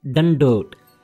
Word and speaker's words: dandot: The word is dandot. – Dandot? – You are saying a dandot. Dandot. It's a not a dadda. It's a dadda. Dandot. Dadda, huh? – Dandot.